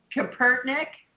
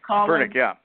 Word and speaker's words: Kaepernick: It's because their quarterback, Colin, I believe his name is pronounced Kaepernick. – Kaepernick.